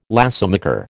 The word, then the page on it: lacemaker: American English